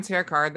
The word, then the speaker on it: Terrah Card: I'm Terrah Card.